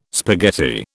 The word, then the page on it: spaghetti: British English